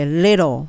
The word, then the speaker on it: little: little じゃなくて little っていうともっと英語っぽい。だから t のとこを L の発音と同じベルの位置、下の位置にすると英語っぽくなります。